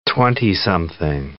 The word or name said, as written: twentysomething